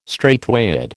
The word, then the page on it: straightway: American English